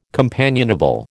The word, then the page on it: companionable: American English